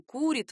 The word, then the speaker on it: курит: Это сейчас мы говорим «курит», «варит», «дарит» и не удивляемся. А ведь не так давно, по меркам истории языка, ударение в этих глаголах было другим — «курит», «варит», «дарит». В этом легко можно убедиться, заглянув в собрание сочинений русских классиков.